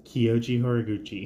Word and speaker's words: Kyoji Horiguchi: But Bellator 279 winner Patchy Mix finds it so prestigious to have Kyoji Horiguchi.